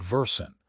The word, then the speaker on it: Versant: A firm called Versant, pronounced V-U-R-Cent, is trying to pivot away from media entirely.